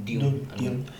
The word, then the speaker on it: Dune: ¿Cómo se pronuncia? ¿Dune o Dune? Dune.